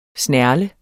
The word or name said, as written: snerle